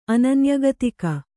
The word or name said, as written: ಅನನ್ಯಗತಿಕ